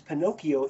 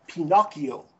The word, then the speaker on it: Pinocchio: But all this pronounces Pinocchio in the correct way, which is Pinocchio, okay? Which is, that's how you'd say it in Italian, Pinocchio.